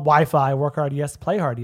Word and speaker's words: WHYPHY: WHYPHY. Pronounced Wi-Fi.